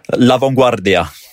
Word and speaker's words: La Vanguardia: La Vanguardia.